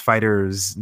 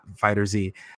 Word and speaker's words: FighterZ: Going on to best fighting game, Dragon Ball FighterZ, it's pronounced Fighters, not FighterZ, that took home the gold.